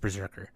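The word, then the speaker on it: Brzrkr: I don't know how you pronounce that. It's B-R-Z-R-K-R. Berserker. I think it's just Berserker.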